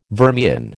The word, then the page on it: vermian: American English